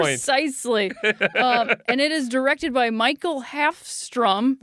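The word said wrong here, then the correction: Mikael Håfström: Mikael Hafstrom.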